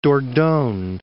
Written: Dordogne

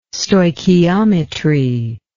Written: stoichiometry

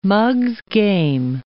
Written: mug's game

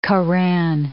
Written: Koran

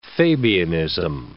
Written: Fabianism

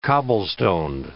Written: cobblestoned

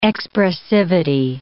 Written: expressivity

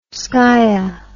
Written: skia

